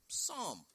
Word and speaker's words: Psalm: In this crazy English language, the P is silent. So we typically say Psalm.